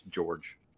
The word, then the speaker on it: Jorg: I asked Mark Lawrence way back when, and he told me it was pronounced George. So George? – Can't have it.